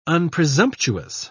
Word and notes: unpresumptuous: British English

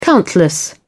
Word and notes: countless: British English

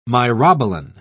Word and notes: myrobalan: British English

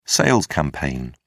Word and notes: sales campaign: British English

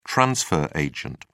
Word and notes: transfer agent: British English

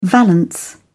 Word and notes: valance: British English

